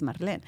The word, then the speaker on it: Marlén: Your name is Marlin. But then, you know, it has an R. So, like, telling people to pronounce the R is hard if you don't mm-hmm. speak Spanish.